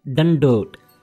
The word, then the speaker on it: dandot: The word is dandot. – Dandot? – You are saying a dandot. Dandot. It's a dad, not a dadda.